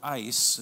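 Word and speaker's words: eis (εἰς): And the word "in" here in the Greek is the word "eis." It's spelled E-I-S, and it means a point reached in conclusion.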